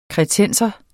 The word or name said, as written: kretenser